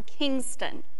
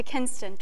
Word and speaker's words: Kinston: Well, the first game I was on, that last September, you said I was from Kingston. – And it's Kinston. – And it's Kinston, because in the 1790s, it changed it from Kingston to Kinston to reflect the fact that, you know, we fought a war and we no longer had a king.